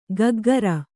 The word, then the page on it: ಕಕ್ಕುಗುರು: kannada